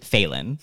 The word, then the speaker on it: Phelan: Phelan. – I was going to say it wrong. I was going to say it like you're bad.